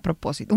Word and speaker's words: propósito: How do you say that? Propósito. Un propósito. – Proposition? – No, propósito.